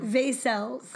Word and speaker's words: Vaicels: How do you pronounce your last name? – Vaicels. – Okay. Yeah. Shauna said it right.